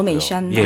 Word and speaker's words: 어메이산: 어메이산. – 예, 어메이산이라고 하기도 하고 아미산이요. – 아미산. 아, 우리나라 발음 또 아미산이라고 아미산.